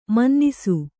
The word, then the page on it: ಮನನಿಸು: mananisu